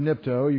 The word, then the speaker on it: nipto: N-I-P-T-O. Nipto. N, is that big enough to see? Yeah. N-I-P-T-O. Long O. Nipto. Almost sounds take a dip.